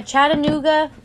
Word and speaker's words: Chattanooga: A Chattanooga...